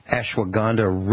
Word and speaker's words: ashwagandha: And I added, just arrived over the weekend, ashwagandha root power. Am I saying that right? A-S-H-W-A-G-A-N-D-H-A, root powder.